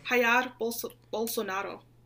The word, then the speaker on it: Jair Bolsonaro: Jair Bolsonaro.